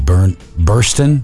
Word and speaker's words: Burstyn: Did I pronounce her name right? Bernstein? – Ellen Burstyn? – Burstyn, I'm sorry. Ellen Burstyn.